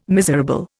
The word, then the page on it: miserable: Indian